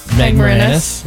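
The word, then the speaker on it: Marinis: So it is created by Shonda Rhimes with Meg Marinus. Is that how you say it? Do you guys know? – Meg Marinus. – Yep.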